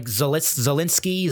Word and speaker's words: Zelensky: Is it Zelensky? – Like Zelensky.